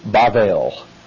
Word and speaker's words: Babel: B-A, and it's pronounced with a soft B in Hebrew. It's Babel.